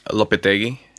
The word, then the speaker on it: Lopetegui: First of all, how do we pronounce his name? – Lopetegui. – Lopetegui? – Yeah, it's a Basque name.